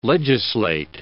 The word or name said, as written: legislate